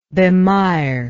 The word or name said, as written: bemirement